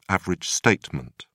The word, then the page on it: average statement: British English